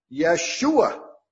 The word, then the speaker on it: Yeshua: I mean, even in our use of Jesus, that's not the correct pronunciation of Jesus either. Do you remember what it really is? Yeshua.